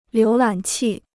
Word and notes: 浏览器: liú lǎn qì

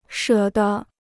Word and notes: 舍得: shě de

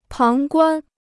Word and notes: 旁观: páng guān

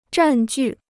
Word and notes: 占据: zhàn jù

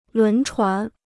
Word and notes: 轮船: lún chuán

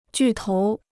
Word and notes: 巨头: jù tóu

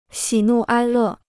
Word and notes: 喜怒哀乐: xǐ nù āi lè

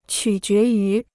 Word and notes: 取决于: qǔ jué yú

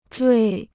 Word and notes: 坠: zhuì

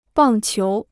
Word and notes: 棒球: bàng qiú

